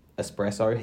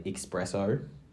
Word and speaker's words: espresso: The next word, espresso, he has to say expresso.